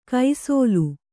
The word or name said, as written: ಕೈಸೋಲು